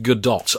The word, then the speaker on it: Gadot: And her name actually is pronounced Gadot, apparently, which I did not realise until I saw the TV spots for this. You would have assumed it was like Brigitte Bardot, it was Godot.